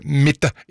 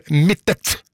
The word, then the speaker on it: Mittet: og så påpeker det Anne Soli at Mitte er et stedsnavn i Rauma kommune, og navnet har alltid blitt uttalt med en tydelig T til slutt, akkurat slik det skrives. Derfor er det ganske frustrerende å høre Mitte i stedet for Mittet.